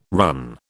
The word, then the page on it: run: British English